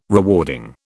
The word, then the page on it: rewarding: British English